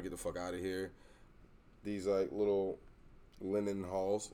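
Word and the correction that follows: Leinenkugel's: Is that how you say it? – Lion and Kugels. – Lion and Kugels. Oh, I thought that was an H.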